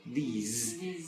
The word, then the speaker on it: these: La S la pronunciamos un poquito más como Z. En vez de this, these. Y sonreímos más. This and these.